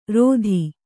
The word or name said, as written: ರೋಧಿ